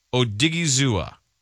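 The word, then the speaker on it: Odighizuwa: My guy is Ola Adigizua, defensive end, the U of CLA. – Okay, according to this, it is pronounced Owa Mabe Odigizua.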